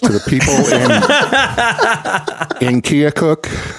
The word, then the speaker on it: Keokuk: Well, Keokuk.